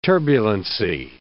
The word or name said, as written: turbulency